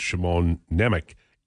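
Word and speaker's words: Šimon Nemec: Now, the reason I can I know it's it's Shimon Nemec is we were talking to Mike Mor- Morreale yesterday, and that's how he pronounced his name.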